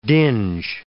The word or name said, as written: dinge